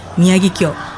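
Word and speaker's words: Miyagikyo: Do I pronounce that right? How do you say it? – Yoichi Miyagi-kyo. – Miyagi-kyo.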